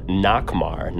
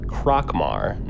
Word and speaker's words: Nokmar: Crow had a letter from, I was pronouncing his name wrong the whole time, Nokmar, not Krokmar, Nokmar.